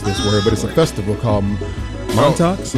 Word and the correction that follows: Montreux: Montreux. – Spell it for us, Chuck. – It is spelled M-O-N-T-R-E-U-X. – And how did you say it? – And I said Montox.